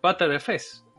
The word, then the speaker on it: Btrfs: Eh, hay gente que lo pronuncia así, o sea, gente relacionada con el proyecto. Butter FS, de la manteca.